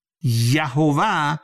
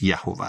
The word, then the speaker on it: یهوه: احیه، املاش، یهوه به احتمال بسیار قوی تلفظشه. چرا میگم به احتمال بسیار قوی؟ چون متون تورات کسر و فتح و این چیزا نداشتن، بنابراین 100% نمیتونیم بگیم، ولی به احتمال بسیار قوی تلفظش یهوه است.